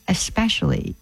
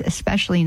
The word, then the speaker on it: especially: I don't know why, but there is no X in especially. It's especially not especially.